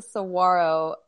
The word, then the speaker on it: Saguaro: The G is not there. It's Saguaro.